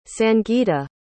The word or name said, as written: sangita